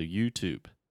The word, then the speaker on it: YouTube: And almost always, when you have an E at the end of the word, not always, but 90% of the time, let's say, the E is silent. So when you think about things like name, game, same, tube, uh, you get the point. Most of the time, we don't pronounce the E at the end of words. So YouTube.